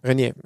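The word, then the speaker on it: Reinier: Wie, wie wird er ausgesprochen? Renier. Renier, ja.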